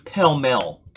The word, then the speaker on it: Pall Mall: It's Pell Mell, Tennessee. They say Pall Mall, that's how it's spelled, but it's beautiful place.